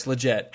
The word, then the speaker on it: Lletget: Sebastian Lletget, whose last name I'm mispronouncing. – No, I think it's pretty close. Lletget.